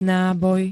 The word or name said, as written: náboj